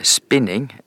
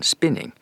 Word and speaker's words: spinning: Ja, det er vel så enkelt som at eh, spinning, som jeg ikke driver på med selv om jeg er mosjonist, det har vi fått fra engelsk i nyere tid, og da er uttalen spinning. Men det er jo samme ordet, og spinning er jo den normale tradisjonsprega norske uttalen, og spinna.